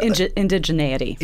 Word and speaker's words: Indigeneity: How do you say this word? Indigeneity? – Indigeneity.